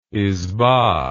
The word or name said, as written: isba